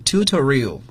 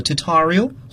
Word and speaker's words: tutorial: Tutorial 也可以读作 tutorial 或者是 tutorial